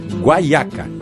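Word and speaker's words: guayaca: E no espanhol, praticamente da mesma forma que pronunciamos no português, apenas se escreve com Y. Mas a pronúncia é guaiaca.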